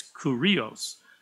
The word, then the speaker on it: Kyrios: Well, here we are in the New Testament, so we're in the Greek, and that word Lord is Kyrios, or it looks like when they pronounce it for me, it's Kurios.